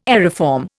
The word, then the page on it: aeriform: Indian